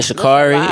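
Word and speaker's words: Sha'Carri: First of all, ain't it Shikari? You gonna say it right? – We talking about Shikari.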